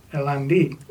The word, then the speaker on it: Hirani: Hirani. Yeah, you have to. They just silent. Roll the R just a little bit.